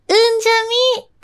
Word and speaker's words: 海神: そして、中野にある沖縄料理屋さん、海神と書いて、うんじゃみと読むみたいです。